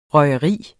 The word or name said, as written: røgeri